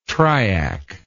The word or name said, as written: triac